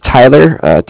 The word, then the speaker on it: Tyler: Yeah, my name is Tyler, T-Y-L-E-R, and I'm actually calling with Cardiff. It's spelled C-A-R-D-I-F-F, as in Frank Frank.